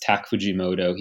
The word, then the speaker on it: Tak Fujimoto: I think it's, his name is pronounced Tak Fujimoto.